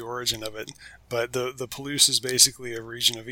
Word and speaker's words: Palouse: Palouse, I said it right? – Palouse. – Palouse.